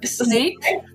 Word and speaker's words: Sneek: But the first one is Sneek. – Snake. So with the double E, right? Snake. – Exactly.